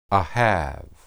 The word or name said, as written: ahab